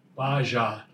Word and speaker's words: Bajaj: Bajaj. – Bajaj? – Bajaj. – Bajaj. – Bajaj. Bajaj. They don't really say the J's that well, I think.